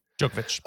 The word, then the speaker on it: Djokovic: Novak Djokovic. Is that right? Novak Djokovic? – Djokovic. – We'll get to Djokovic. Djokovic or Djokovic? – I say itch, but maybe I'm wrong. – Djokovic.